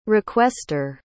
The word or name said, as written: requester